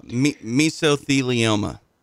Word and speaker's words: mesothelioma: We were, when Steve passed away in 1980 at the age of 50 from mesothelioma, I would never say it right, cancer, which finally got him in the end. – Mesothelioma. – Mesothelioma, thank you.